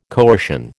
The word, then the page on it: coercion: American English